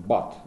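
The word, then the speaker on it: but: Например, слово там «бат», да, оно пишется «би-юти», читается «бат». А точно так же пишется слово «пут», «пи-юти». Это «бат», а это «пут». И почему это читается «бат», а это «пут», никто объяснить не может, понимаете?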